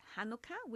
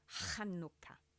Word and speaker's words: Chanukah: It's pronounced Chanukah with a guttural sound for the H and is the Jewish festival of life. So it's pronounced as Chanukah.